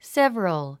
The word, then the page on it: several: sév ə rəl; セブロゥ